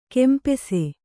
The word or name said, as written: ಕಂಪಾಸು